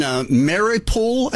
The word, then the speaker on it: Mariupol: And there's a history teacher who in Mariupol, I believe is how you say it. – Okay. Yes, right.